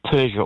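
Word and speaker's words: Purja: How do you say his surname? – Nirmal Persia, or by his alternative name of Nimstai.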